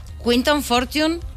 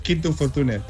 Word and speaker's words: Quinton Fortune: Quinton Fortune, ¿lo hemos pronunciado bien? – Sí, sí. Bueno, aunque normalmente en España es Quinto Fortune, pero es igual.